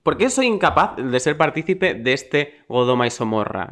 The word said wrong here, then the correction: Sodoma y Gomorra: en el que vivimos? Se dice así, ¿no? Sodoma y Gomorra.